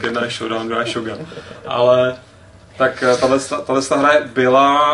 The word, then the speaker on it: Shogun: A to sice oznámení pokračování Shogun, nebo Shogun? – Shogun, samozřejmě. – Shogun, Tomodor. – Tady s naším místním japanologem zprávujeme, že Shogun je ta správná výslovnost.